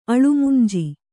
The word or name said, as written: ಅಳುಮುಂಜಿ